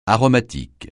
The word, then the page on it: aromatique: francais